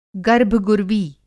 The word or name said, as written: गर्भगुर्वी